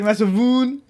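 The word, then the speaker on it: Massivoon: Its Japanese name is Masavune, which is a combination of massive, as in large, and boon, but like it means like mosquito in Japanese, but like it's spelled, you get it, okay? You're listening to me, it sounds, you see the boon, it's like Masavune.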